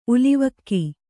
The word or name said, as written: ಉಲಿವಕ್ಕಿ